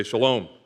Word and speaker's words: shalom: Everybody say shalom. If you're over in Israel, this is how you say hi or goodbye.